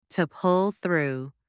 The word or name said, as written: to pull through